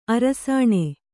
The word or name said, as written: ಅರಸಿಣ